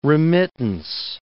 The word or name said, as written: remittance